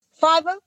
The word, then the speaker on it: Fiverr: I know it's spelled Fiverr. So it could be Fever. – Fiverr? Yeah. – All right. That was close. – Yeah. It's spelled weird looking. So it throw you off.